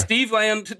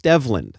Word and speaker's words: Stevland: So we've talked about this in depth, and it's pronounced, and I don't know if you think this Steve is funny Land? Or less funny, pronounced Stevland.